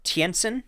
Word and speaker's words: Tientsin: So he was involved in the Battle of Tientsin. Yeah, I actually said that right.